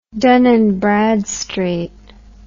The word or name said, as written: dun and bradstreet